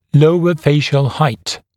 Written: lower facial height LFH

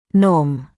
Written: norm

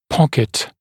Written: pocket

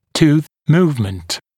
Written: tooth movement

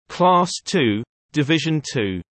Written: Class II Division 2

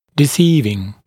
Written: deceiving

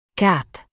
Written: gap

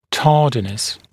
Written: tardiness

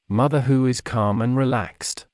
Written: mother who is calm and relaxed

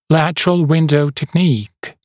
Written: lateral window technique

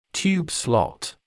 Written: tube slot

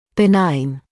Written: benign

